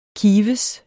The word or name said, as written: kives